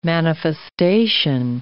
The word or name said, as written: manifestation